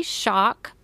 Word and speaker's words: Shauck: My email address is K Shock, spelled, my last name is S-H-A-U-C-K.